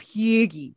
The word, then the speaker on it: hygge: Well, on that note, you know, for a long time here at Satellite Sisters, we've been tracking the, Norwegian tradition of hygge, right? – wonderful pronunciation this morning.